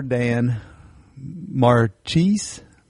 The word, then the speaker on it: Marchese: Ah, poor Dan. Marchese? Marchese. M-A-R-C-H-E-S-E. – Oh, make sure that everybody knows his name. – Well, I want to pronounce his name right. Dan Marchese.